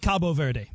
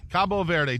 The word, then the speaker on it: Cabo Verde: I like Cabo Verde. – Cabo Verde.